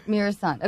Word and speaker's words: Muresan: Muresan. – Muresan. Okay, yeah, thank Muresan. you. Muresan. – okay, better big man performance: Kareem Abdul-Jabbar in Airplane or George Muresan in Billy Crystal's My Giant.